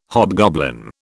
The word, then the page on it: hobgoblin: British English